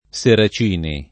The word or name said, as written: Seracini